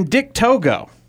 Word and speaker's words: Togo: Then Dick Togo. You have to be careful how you pronounce Dick Togo's name, because it almost sounds like Dick to go.